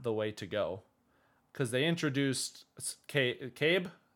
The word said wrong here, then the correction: Cad Bane: Cad Bane.